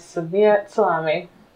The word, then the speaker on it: Samiat Salami: It is Samiat Salami. – But I don't pronounce the T, right? Or I do? – Oh, that's a back and forth thing.